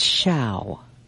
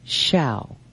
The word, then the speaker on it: Hsaio: From the beginning, and I pronounced it every way. It's shall. – It is. – As in shower, as in shower. – Anyway. – And it's spelled H-S-A-I-O. Yeah, but we have to learn shall.